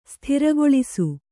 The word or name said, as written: ಸ್ಥಿರಗೊಳಿಸು